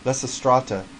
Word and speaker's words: Lysistrata: Lysistrata. – Lysistrata.